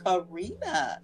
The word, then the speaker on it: Karina: like that, but the older generation seems to pronounce it a lot better on the first try, you know, and sometimes I'm saying, are folks, do they listen to phonics in school? Because it's Karina, right.